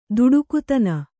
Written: ಧಡಿಗತನ

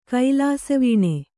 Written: ಕೈಲಾಸವೀಣೆ

